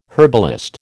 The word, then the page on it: herbalist: American English